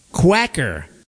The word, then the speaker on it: Quaker: As it turns out, the word Quaker, as in Quaker oats, is pronounced Quacker. That's how you sound out those letters in Spanish.